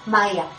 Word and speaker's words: Mahya: Me ubican siempre como Maya, el cielo te abraza. Mi nombre se escribe con M-A-H-Y-A, Maya, el cielo te abraza.